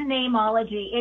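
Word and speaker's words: Neimology: It's Nameology. It just has a Latin spelling because you can't trademark a common word like name. And so I spelt it how name is said in Latin, which is N-E-I-M, but I still pronounce it Nameology.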